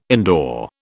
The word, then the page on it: indoor: British English